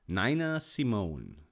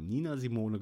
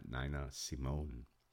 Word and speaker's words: Nina Simone: Eines der vielen unglaublichen Lieder der berühmten amerikanischen Sängerin Nina Simone. Ich glaube, so ist der Name richtig ausgesprochen. Wir haben aber auch in unserem jugendlichen Leichtsinn schon mal Nina Simone gesagt oder Nina Simone.